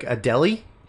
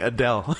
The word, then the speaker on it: Adele: No, you're mispronouncing Adele. – Talking about Adele? – Adele is my favorite singer. It's like Akinyele, who didn't put it in your mouth. Oh, I've always been pronouncing Adele like Akinyele.